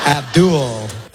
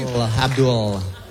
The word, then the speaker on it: Abdul: We gotta say it like that, man. It's creepy. – Abdul. – That's how it's pronounced. – Holds on to the L a little too long.